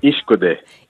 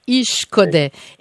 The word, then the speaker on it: Ishkode: Est-ce qu'on prononce Ishkode ou Ishkode, Samian? – Ishkode. – Ishkode.